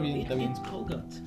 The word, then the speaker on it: Colgate: Cálgate. Se pronuncia cálgate. ¿Cómo? Cálgate. ¿Por qué cálgate? Esta pasta de dientes. Ah, Colgate. Se dice colgate, pendeja.